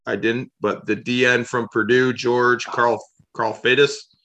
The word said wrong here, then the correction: Karlaftis: Karlaftis. – Karlaftis.